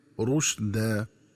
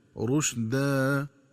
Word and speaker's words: رشدا: وكذلك ينبغي التنبه للنطق الصحيح لكلمة رشدا، فتقرأ بضم الراء وتسكين الشين هكذا رشدا، ومن الخطأ قراءتها بفتح الراء والشين هكذا رشد.